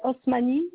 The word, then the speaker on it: Osmani: Alors, c'est Osmani Youssef. – Ça s'écrit comment? A-S? – Non, Osmani.